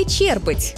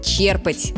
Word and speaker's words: черпать: Черпать воду или черпать? Вот тут многие спотыкаются. Правильный вариант – черпать, с ударением на первый слог.